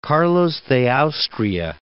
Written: Carlos de Austria

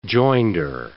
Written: joinder

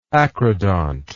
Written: acrodont

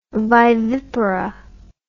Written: vivipara

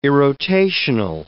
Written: irrotational

